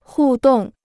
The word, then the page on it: 互动: hù dòng